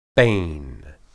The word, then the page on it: beyn: Hebrew